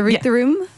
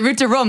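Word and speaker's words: Route du Rhum: So this November is the route to, am I pronouncing that right? The route to room? – So it's called the route to rum, like rum that you drink.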